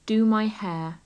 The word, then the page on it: do my hair: English